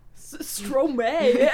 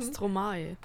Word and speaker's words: Stromae: Stromae. Bitte ein bisschen afrikanischer aussprechen. – Stromae. – Stromae.